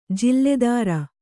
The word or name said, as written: ಜಲಾಧಾರ